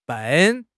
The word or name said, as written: ben4